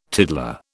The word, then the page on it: tiddler: British English